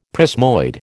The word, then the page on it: prismoid: American English